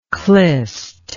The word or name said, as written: Khlyst